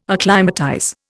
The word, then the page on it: acclimatize: Indian